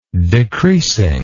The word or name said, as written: decreasing